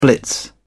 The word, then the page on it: blitz: British English